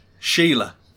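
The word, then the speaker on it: Síle: S-I-L-A. – Sile. – Sile. – Sile. – Sheila.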